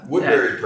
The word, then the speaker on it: Woodbury: Woodbury's perfect.